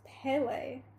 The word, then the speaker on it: Pele: So, I guess Pili is pronounced Pele, so I'm already saying it wrong.